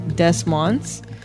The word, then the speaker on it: Des Moines: But I believe that's pronounced differently. – It's Des Moines. – Des Moines? – Des Moines.